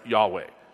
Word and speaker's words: Yahweh: King James uses Jehovah all the time. Guide me, O thou great Jehovah is one of my favorite hymns, but it's probably not the right way to pronounce Yahweh.